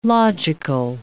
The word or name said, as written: logical